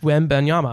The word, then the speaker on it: Wembanyama: I'm going to butcher his last name. Victor Wembanyama. – I'm just going to call him Victor. – Victor Wembanyama. I know I did it. I absolutely nailed it.